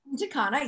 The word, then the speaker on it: Punta Cana: Punta Cana, yep, which okay.